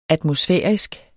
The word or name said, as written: atmosfærisk